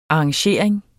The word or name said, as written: arrangering